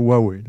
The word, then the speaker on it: Huawei: J'ai jamais trop comment dire Huawei. C'est Ça, Huawei. Euh, je te le confirme pour travailler directement avec eux autres. C'est comme ça que ça se prononce.